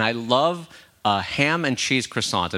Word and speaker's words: croissant: Wait, how did you say that word? – Croissant. I actually pronounce it right. It's croissant.